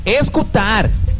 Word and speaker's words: escutar: En portugués, siño, se dice escutar. – Escutar. – Luisinha, Fernandinha, tienes que escutar. – Escutadora. – Escutadora. Escutar.